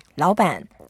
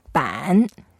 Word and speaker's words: 老板: 老板, 老. – 老. – 老 means old. 板. – 板. – 板. Both are third tone, so in this case, we make the first word 老 lighter, so that may sound slightly like second tone.